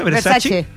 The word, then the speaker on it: Versace: Versace.